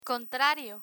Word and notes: contrario: Spanish

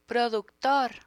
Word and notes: productor: Spanish

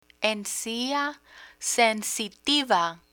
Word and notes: encia sensitiva: Spanish

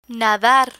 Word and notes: nadar: Spanish

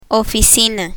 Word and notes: oficina: Spanish

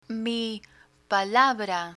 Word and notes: mi palabra: Spanish

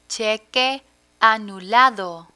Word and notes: cheque anulado: spanish

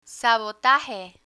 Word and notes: sabotaje: spanish

